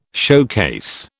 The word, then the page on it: showcase: British English